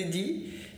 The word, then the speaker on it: Die: É D-I-E, tá? Die.